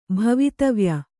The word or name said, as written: ಭವಿತವ್ಯ